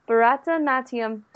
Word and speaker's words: Bharatanatyam: And on air with us is Sujata and Shreya, and they've been telling us about Bharatanatyam dance.